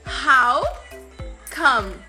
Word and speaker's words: come: Esse come, atenção, ele termina com a palavra é, né? Mas não fala em come, tá? Termina no M. O som, quando você pronunciar o som, ele vai acabar no M.